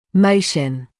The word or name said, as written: motion